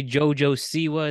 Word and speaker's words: JoJo Siwa: So, you know that celebrity JoJo Siwa? Is that how you pronounce it? – JoJo? Yeah. – It is how you pronounce that.